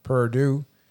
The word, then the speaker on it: Purdue: I'm going to go Nebraska uh, to uh, to beat Peru, Purdue. Sorry, I always say it wrong. Purdue.